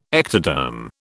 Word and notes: ectoderm: British English

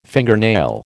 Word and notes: fingernail: American English

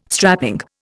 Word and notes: strapping: Indian

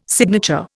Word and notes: signature: Indian

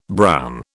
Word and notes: brown: British English

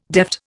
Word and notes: deft: Indian

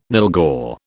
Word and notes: nylghau: British English

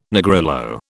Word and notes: Negrillo: British English

